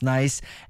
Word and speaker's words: Nyce: » Il y a une fille qui m'a écrit, elle s'appelle Annick Côté et je crois que son nom d'artiste se prononce Nice, N-Y-C-E, sort un album le 12 décembre.